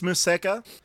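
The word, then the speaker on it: Museca: But the one I really want to talk about is Musica or Musica. I never really was sure how to pronounce it. Musica. I guess I'm trying to say it Japanese. – I believe it's Musica.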